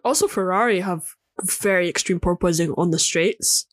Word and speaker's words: porpoising: Purposing? What do you say? – Porpoising. – Porpoise? – Like a porpoise. Like a dolphin.